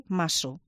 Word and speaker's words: mazo: Pues te dicen que además no se debería ni siquiera describir mazo, que se debería describir maso, como se pronuncia.